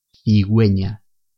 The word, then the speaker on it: cigüeña: En el español escrito, para señalar que debemos pronunciar esta letra, utilizamos la diéresis, que son dos puntos horizontales sobre la letra U de esta sílaba. Por ejemplo, con la vocal E, cigüeña, con la vocal I, whisky. Si no utilizamos la diéresis, al leer estas palabras diríamos figueña o giski y eso está mal.